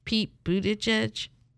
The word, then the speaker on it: Buttigieg: Pete Buttigieg.